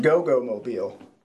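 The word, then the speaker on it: Goggomobil: Go-Go Mobile. And I'm going to die on this hill. That's how you pronounce it.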